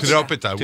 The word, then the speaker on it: Tiropita: How do you say it? Trikopita. – Tiropita? – Tiropita, which means three cheeses, right?